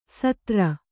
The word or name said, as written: ಸದರಾ